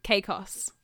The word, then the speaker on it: Caicos: Caicos.